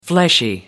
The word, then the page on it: fleshy: American English